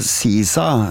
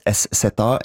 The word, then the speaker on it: SZA: S-Z-A. Yeah. S-Z-A, how do you go about pronouncing this? Well, it's pretty straightforward once you know. SZA. SZA. SZA. SZA. Pretty easy. SZA.